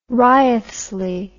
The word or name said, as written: Wriothesley